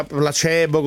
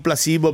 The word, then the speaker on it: Placebo: Guardate che placebo si pronuncia placebo con C ed E, e non placebo perché non è inglese ma latino.